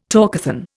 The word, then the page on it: talkathon: Indian